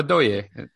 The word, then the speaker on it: Ndoye: I want to not pronounce the N or the D, and I forget which one I have to pronounce, basically. But anyways, I don't think you pronounce the N. – So Ndoye, Papa Ndoye. – No, Ndoye.